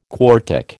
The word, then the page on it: quartic: American English